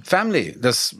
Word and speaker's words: Family: Family, das wird einfach nicht ausgesprochen, das I. Außer in Liedern oder in Gedichten, wie zum Beispiel in dem schönen, auch dir schon lange bekannten Lied Running in the Family.